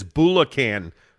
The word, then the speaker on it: Bulacan: Greg also clarified your pronunciation. He said it's Bulacan, Philippines, and that wasn't me, Jake.